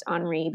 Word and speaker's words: Henri: The decoy car left, and this guy named Henri, which is really Henry, but pronounced Henri because Paris European.